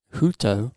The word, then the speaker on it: jhuto: Um, and interestingly, or fun fact, comes from the Bengali word huto. I think that's how you say it. J H U T O, uh, which means twisted hair.